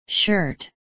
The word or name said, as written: shirt